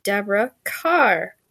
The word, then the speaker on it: Deborah Carr: No, my name is, as it's written, really Deborah Carr. Yeah, that's right.